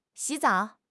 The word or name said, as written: xi3 zao3